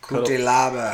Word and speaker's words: Cutelaba: Co-main event tomorrow night is Devin Clark versus Island Cutibola. – Cutelaba. – Cutelaba. I fucking butchered that.